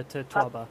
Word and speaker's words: totoaba: Am I pronouncing it correctly? – Yeah, the totoaba.